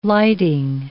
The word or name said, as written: lighting